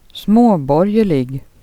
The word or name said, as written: småborgerliga